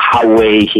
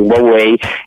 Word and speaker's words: Huawei: che poi in realtà si pronuncia Huawei in cinese, noi l'abbiamo italianizzato in Huawei,